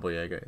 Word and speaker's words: Boyega: Yeah, John Boyega.